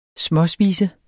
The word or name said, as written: småspise